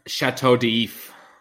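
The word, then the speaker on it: Château d'If: Chateau d'If.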